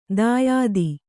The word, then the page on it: ದಾಯಾದಿ: dāyādi